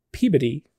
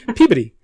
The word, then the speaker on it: Peabody: And she lives in Peabody, Massachusetts with her family.